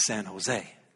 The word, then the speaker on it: San Jose: Now, here in California, what we do is we pronounce all those J's as H's. And so instead of San Jose, it is San Jose.